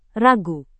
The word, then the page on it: ragu: Indonesian